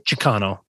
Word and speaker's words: Chicano: Chicano. My bad. – Yes. Thank you. Thank you. You actually said that correctly.